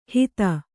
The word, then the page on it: ಹಿತ: hita